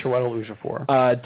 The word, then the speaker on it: Chiwetel Ejiofor: Now, okay, I don't know how to pronounce his name. – It's Chiwetel Ejiofor.